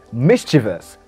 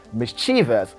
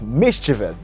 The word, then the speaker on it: mischievous: Now, when you listen to the Queen, she said mischievous. That's the right pronunciation. I do hear many people say mischievous, getting the wrong pronunciation. Now, the right pronunciation is mischievous. The stress falls at the first syllable. Mischievous, okay? – That mischievous.